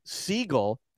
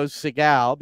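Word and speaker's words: Segal: It's pronounced Siegel, as he told me at the hotel, like the bird, because everybody wants to go Seagal because we've all seen the Steven Seagal movies, right. So, but it's Siegel.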